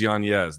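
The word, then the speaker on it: Yáñez: And by the way, people are like, you're pronouncing his name wrong. It is Yanez. The N is actually an Enye. He just doesn't pronounce it because people botch it.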